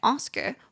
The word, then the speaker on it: Oscar: 那要怎么念这个男生的名字呢？就是 Oscar，Oscar。那所以奥斯卡奖，你就是在 Oscar 后面加一个 s，然后通常我们也会在 Oscars 前面再加一个 the。